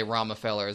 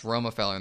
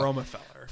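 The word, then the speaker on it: Romefeller: I want to say instinctually say Romafeller as well, but it's pronounced Romafeller in the show. – Romafeller.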